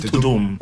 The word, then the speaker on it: Tudum: How do you pronounce that? – I thought it was Tadum. I think yeah, it's Tudum.